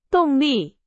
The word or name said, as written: 动力